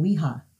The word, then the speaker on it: Ouija: which, how do they pronounce it? Weeha. – Oh, did they? – Yeah, Weeha. Yeah.